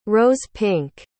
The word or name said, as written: rose-pink